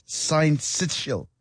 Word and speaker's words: syncytial: Pronounce it as syncytial virus.